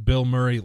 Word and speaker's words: Bilmuri: And yes, it's pronounced Bill Murray like the Bill Murray. It's spelled B-I-L-M-U-R-I, but it is pronounced exactly like Bill Murray.